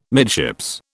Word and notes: midships: British English

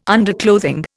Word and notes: underclothing: Indian

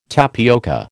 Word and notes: tapioca: American English

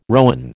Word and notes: rowan: American English

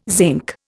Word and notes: zinc: Indian

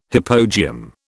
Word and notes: hypogeum: British English

